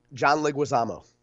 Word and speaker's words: John Leguizamo: John Leguizamo. – Leguizamo and Obama.